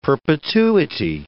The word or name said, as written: perpetuity